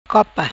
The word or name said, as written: Copa